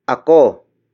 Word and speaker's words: ako: Ako. Ako. Ako is spelled as A-K-O.